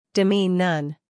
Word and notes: demean: dɪˈmiːn

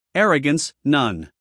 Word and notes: arrogance: ˈærəɡəns

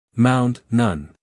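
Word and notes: mound: uk maʊnd; en-us maʊnd

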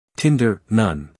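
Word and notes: tinder: uk ˈtɪndə(r); en-us ˈtɪndər

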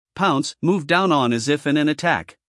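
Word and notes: pounce: uk paʊns; en-us paʊns

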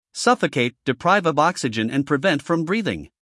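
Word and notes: suffocate: uk ˈsʌfəkeɪt; en-us ˈsʌfəkeɪt

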